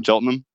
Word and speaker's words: Cheltenham: Cheltenham.